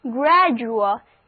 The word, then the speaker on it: gradual: 现在我们来看这个单词 gradual, G-R-A-D-U-A-L, gradual, 字母组合 u-a 发 rua 的音，而字母 D 发音改为 G。跟我读这个单词 read after me, 就是 gradual。